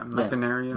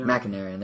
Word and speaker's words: Machinarium: There's Joseph from Machinarium. Did I pronounce that right? – Yeah, Machinarium. – Machinarium, there we go.